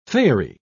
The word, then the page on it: faery: British English